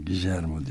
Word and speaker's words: Guillermo: Guillermo diye okunuyor. – Guillermo mu? Guillermo del Toro gibi.